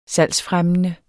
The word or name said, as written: salgsfremmende